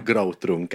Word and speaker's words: gråtrunk: But moving on from gråtrunk, how do you pronounce it? – Gråtrunk. – Gråtrunk. Okay, that's bad pronunciation on my part.